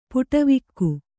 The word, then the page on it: ಪಡಿವಾಘೆ: kannada